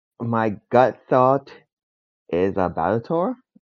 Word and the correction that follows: Balatro: Balatro. – Balatro. One day I will learn how to pronounce it properly, but yes.